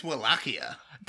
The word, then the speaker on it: Wallachia: I think it's Wallachia. – That's Wallachia. Always what I thought.